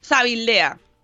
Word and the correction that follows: Zalvidea: Es que siempre me equivoco con, con Zabala, Zalvidea. ¿Ves? Lo he dicho mal.